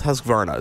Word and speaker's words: Husqvarna: I'm going to go with Husqvarna.